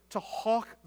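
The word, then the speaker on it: hough: So God told Joshua to go and to hawk the horses.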